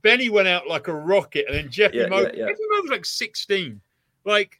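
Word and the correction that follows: Jefimova: Ifimova. Ifimova. – Is that how you say it? – Yeah. – Or is the J silent? Is that a silent J? – Yeah, silent.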